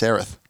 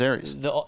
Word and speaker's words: Aerith: It's Aerith.